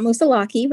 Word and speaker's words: Moosilauke: What is it pronounced? And I'm from California, so cut me some slack. Mount Musiliki. – It's Mount Musilaki, but you're very close.